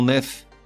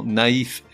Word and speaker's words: naïf: Ainsi, on ne dit pas Patrick est un grand nef, mais Patrick est un grand naïf, N-A-I tréma F.